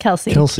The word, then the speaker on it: Kelce: But if you're Mr. and Mrs. Kelce, How do you say it? – Kelsey. – Kelsey.